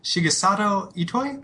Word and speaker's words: Shigesato Itoi: Yep, and unlike uh, most JRPGs, Earthbound is pretty much the singular expression of the essayist uh, Shigesato Itoi.